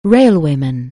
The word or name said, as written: railwayman